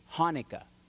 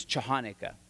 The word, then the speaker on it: Hanukkah: Hanukkah. Or if you're from where I grew up in the San Fernando Valley and really can't pronounce things, Chahanukkah.